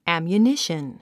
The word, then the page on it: ammunition: æ`mjuníʃən; アミュニション